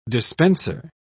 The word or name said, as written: dispenser